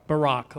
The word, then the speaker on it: Barak: Actually, she says, you know, Barak won't go without her I think I'm pronouncing his name wrong too. I don't know if it's Balak or Barak. Now I've got myself. Huh? Barak. Okay. Barak.